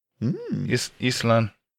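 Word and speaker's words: Ísland: Yeah, they're trying to sound it out like Island. – That would kind of work for Iceland's pronunciation in Icelandic. Island.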